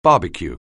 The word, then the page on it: barbecue: anglais